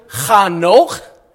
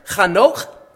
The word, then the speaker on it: Chanoch: She conceived and gave birth to Chanoch, is how you're supposed to pronounce that. Nice phlegmatic sounds there. Chanoch.